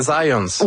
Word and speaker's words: Zajonc: Non, c'est Zajonc. – Ouais? OK. – J'ai-tu pas dit Zajonc? – Vous avez dit plus Zajonc, mais... – J'ai prononcé un petit peu à l'anglais. J'ai prononcé mon polonais à l'anglais.